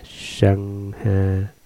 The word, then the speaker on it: Sangha: Tăng nguyên là tăng già, tiếng Ấn Độ đọc là Sangha, dịch nghĩa ra chữ Hán là hòa hợp chung, cùng chứng được ly vô vi giải thoát gọi là ly hòa.